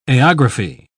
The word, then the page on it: aerography: British English